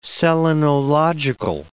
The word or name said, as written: selenological